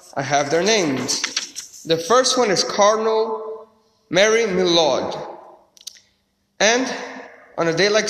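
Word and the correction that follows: Mermillod: Oh, his name is pronounced, I got the pronunciation here. Cardinal Mayer Milord.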